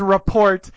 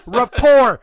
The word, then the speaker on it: rapport: Yeah, Brad Pitt says report, and Ozzy gets so angry. He's not even so angry that they have his memoir. He's angry that Brad Pitt mispronounced a word. – That's right. – He's like, rapport, rapport, you moron, you fucking moron.